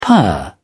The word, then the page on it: purr: British English